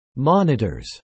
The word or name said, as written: monitors